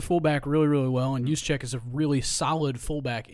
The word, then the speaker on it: Juszczyk: The one thing they don't come out of here unscathed, and it's weird to say it, but big loss here is Juszczyk Am I saying that right? Just check. – Kyle Juszczyk.